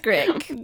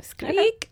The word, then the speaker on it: Skrik: Maybe pronounced Skrik, but it's spelled S-K-R-I-K. – That's way cuter than Scream. – Skrik. I know. Skreek. I like it with like skreek. Yeah. It's got a little shriek rhyme.